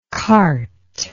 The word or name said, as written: quarte